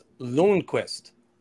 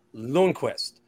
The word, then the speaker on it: Lundqvist: First off, I just want to say it's pronounced Lundqvist. Lundqvist.